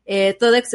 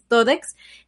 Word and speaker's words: Thodex: Ahora, lo que sucedió con Todex, que de hecho fue el exchange del cual su CEO se fue del país, Todex, se escribe T-H-O-D-E-X, Todex.